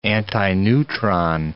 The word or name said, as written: antineutron